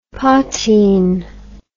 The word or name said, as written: poitin